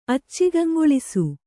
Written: ಅಚ್ಚಿಗಂಗೊಳಿಸು

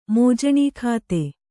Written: ಮೋಜಣೀಖಾತೆ

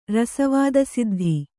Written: ರಸವಾದಸಿದ್ಧ

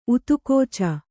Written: ಊದುಗಿಚ್ಚು

